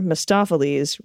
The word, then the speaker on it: Mistopheles: The magical Mr. Mistopheles rhymes with McLotsafleas.